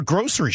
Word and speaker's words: grocery: My buddies are coming in town and they're giving me grief for grocery shopping. And then somebody else is saying that in the Northwest, we pronounce it grocery. – Grocery. – With a C, with like an SH.